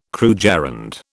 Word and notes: krugerrand: British English

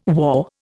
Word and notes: war: Indian

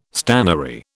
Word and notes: stannary: British English

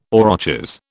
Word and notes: aurochs: British English

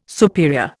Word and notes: superior: Indian

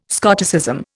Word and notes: Scotticism: Indian

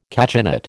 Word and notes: cachinnate: American English

